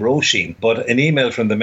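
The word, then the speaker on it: Róisín: Over the over the O and the second I, and it read, it is pronounced Roisin, which means little rose.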